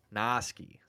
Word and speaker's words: Stepnoski: Stepnowski. – Noski. So you were there. You just didn't say it right.